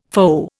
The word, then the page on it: foe: Indian